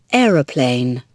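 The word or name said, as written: aeroplane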